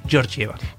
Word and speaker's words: Georgieva: Georgieva, lo dije bien el apellido. – Sí, es Cristalina Ivanova Georgieva.